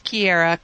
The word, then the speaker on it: Kiera: So it's Kiera, K-I-E-R-A.